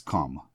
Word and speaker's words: Komm: I'm thinking that the extra syllable, Hilfe versus help, is what led STS to choose a different word, and that word is Komm, K-O-M-M, which is German for Komm, C-O-M-E. Same pronunciation, just a different spelling.